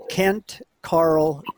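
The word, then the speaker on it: Ken Caryl: Now, uh, Carroll is spelled C A R R O L L, but the uh, folks in uh, this city pronounce the uh, area Kent Carl, Colorado.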